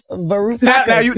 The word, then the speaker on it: Varoufakis: Varoufakis.